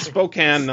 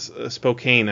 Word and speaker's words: Spokane: I know William DeFries, but his pronunciation of Spokane is not correct. it's Spokane, not Spokane, I think is what he said.